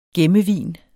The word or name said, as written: gemmevin